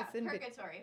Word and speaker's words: purgatory: Hey, is it pregatory? How do you spell? How do you pronounce? – Pregatory. Like where you go before you go to hell? – Or heaven. – Oh. – Yeah, purgatory. – Purgatory. Oh, okay.